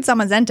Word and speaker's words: Zamazenta: When I heard Zamazenta, I was like, I'm going to remember how to pronounce it because it sounds like magenta.